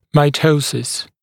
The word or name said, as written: mitosis